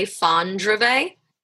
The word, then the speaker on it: Fondrevay: Fondrevay.